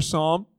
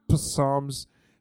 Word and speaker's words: Psalm: Starts with a P. It's like the silent P. I don't know. Psalms, P-S-A-L-M. You're searching it out on your phone.